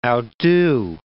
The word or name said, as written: outdo